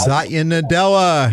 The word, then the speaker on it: Satya Nadella: Satya Nadella.